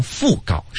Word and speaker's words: 讣告: 哦，刚才说的是布告，但实际上读成讣告是吧？ – 哦，讣就是傅妈的那个姓哈。